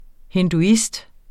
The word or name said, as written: hinduist